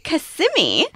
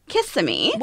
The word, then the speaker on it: Kissimmee: It's actually Kissimmee. So the emphasis is on the sim. And I learned that when we were there 2 weeks ago, because I was calling it Kissimmee.